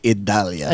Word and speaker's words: Idalia: Idalia? How do you say that one? – Paco. – Oh. Get your adjuster out. – Tropical storm expected to intensify. – It's actually Idalia.